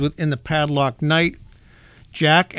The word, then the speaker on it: JATK: This is a new song by Jack. That's pronounced, that's spelled, I should say, J-A-T-K.